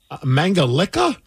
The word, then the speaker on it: Mangalica: We have a special Hungarian pig called a Mangalica pig or Mangalica pig. – Mangalica? – Yes, that's how they say it overseas.